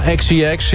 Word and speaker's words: XCX: Nummer 2, Charlie XCX. Zeg ik dat goed? – Ja, XCX, ja.